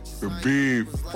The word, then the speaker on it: Khabib: Khabib.